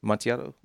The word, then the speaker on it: Amontillado: Is that how you say it? – Amontillado? – Yep. – Amontillado.